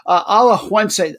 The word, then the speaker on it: Alajuelense: Alajuelense, there's an extra syllable in there I always forget about.